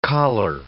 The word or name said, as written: choler